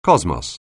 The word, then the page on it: cosmos: anglais